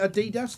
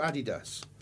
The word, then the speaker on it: Adidas: Well, I mean, you'd have to say, I would imagine, is it Adidas, do you say, or Adidas? Adidas? – Well, I always call it Adidas, mate. – Adidas, I think, is the American pronunciation.